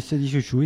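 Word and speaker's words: Xiu Xiu: Ça se dit Xiu-Xiu et ça s'écrit Xiu-Xiu.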